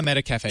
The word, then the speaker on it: Metacafe: Yeah, Metacafe.